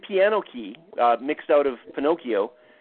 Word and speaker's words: Pinocchio: Well, if you take the word Pinocchio, which is actually correctly pronounced Pinocchio, what you get is piano key.